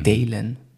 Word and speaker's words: Daylenn: Ja, Dalen. Ah, okay. Also man schreibt es wie der Tag eigentlich, Day mhm. und dann L-E-N-N. Mhm. Dalen. Keine Ahnung, mhm. ja, so. Wurde es zumindest auf der Internetseite ausgesprochen.